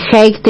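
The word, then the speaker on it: Hague: La histórica interpelación al canciller, no sé pronunciarlo, Haig, Alicia Castro, buen día. – Buen día, sí, canciller Haig, William Haig.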